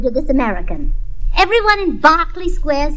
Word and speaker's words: Berkeley Square: But my family had a perfectly top-hole tizzy when I left our home in Berkeley Square. – Now, as one nightingale to another, that is pronounced Berkeley Square.